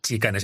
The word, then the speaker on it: Tsikane: Efectivamente, he dicho Sicane, pero efectivamente se deletrea T-S-I-K-A-N-E. Sicane, así pero es Sicane, se pronuncia como una chi.